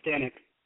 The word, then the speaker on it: Stanek: That's right, Stanek.